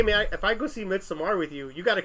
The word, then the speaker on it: Midsommar: Midsommar, yeah, it's like a Swedish pronunciation. – Midsommar.